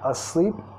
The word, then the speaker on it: asleep: Acá, asleep. Primera sílaba reducida, segunda enfatizada.